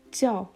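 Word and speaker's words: jiao (叫): Also du, ni, heißen, jiao, die vierte Betonung, jiao, was, shemme, shemme zweite Betonung und hier M, E.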